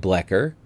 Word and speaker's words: Bloecher: That's spelled B-L-O-E-C-H-E-R, and there may be other pronunciations, but the only folks I was able to find with that name here in Georgia say it like Blecher, so I'm going to go with that.